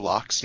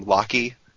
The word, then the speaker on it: Locke: I had a friend when I was a kid who in Final Fantasy VI pronounced Locke's name Locky. So when we were first talking about our favorite characters, he's like, I use Locky all the time. I was like, I think it's pronounced Locke.